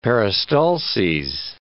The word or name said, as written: peristalses